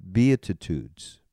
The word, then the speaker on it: beatitudes: For instance, and this is kind of where we're running out of time, but I have to include these new beatitudes.